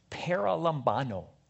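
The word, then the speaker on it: Paralambano (παραλαμβάνω): The word here is paralambano. That's a harder one. I had to have the guy on my Greek software say it like six times. Paralambano. Paralambano.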